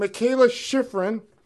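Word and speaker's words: Mikaela Shiffrin: All right, remember the name, Mikayla Shiffrin. Did I pronounce that right? – Yes, you did.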